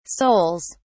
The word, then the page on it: souls: British English